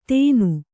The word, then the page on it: ಧೇನು: Kannada